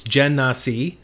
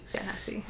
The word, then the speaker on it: Genasi: Uh, I, I, had to look up the pronunciation because I was tired of like not knowing, and it's okay. it's Genasi. – Genasi.